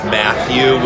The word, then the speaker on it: Matthew: You might have to search me in YouTube under my full name, which is Matthew with 2 T's, Paul Tucciarone, TUCCIARONE.